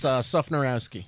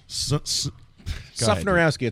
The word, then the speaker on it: Sufnarowski: Because, you know, it's just like it's spelled. – Yeah. I knew there was a stock answer to that. – It's Sufnarowski. Sufnarowski. It's a Polish royal bloodline.